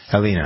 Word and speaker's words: Halyna: Halyna Hutchins.